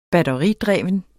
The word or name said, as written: batteridreven